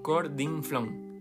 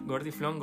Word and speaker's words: gordinflón: Toda la vida fue gordinflón pero tanto la gente la pronunció mal, gordiflón, gordiflón, gordiflón, se quedó así como acepta.